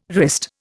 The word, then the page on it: wrist: Indian